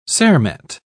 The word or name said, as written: cermet